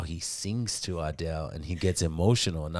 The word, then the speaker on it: Adele: Adele.